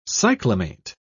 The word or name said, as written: cyclamate